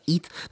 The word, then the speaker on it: ETH: That's what it's pronounced as, ETH.